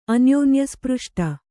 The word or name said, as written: ಅನ್ಯೋನ್ಯಸ್ಪೃಷ್ಟ